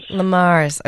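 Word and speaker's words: Le Mars: Lamars. – Okay. Big town, small town. – Little E, big M. – Oh, yes. So French.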